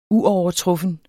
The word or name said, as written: uovertruffen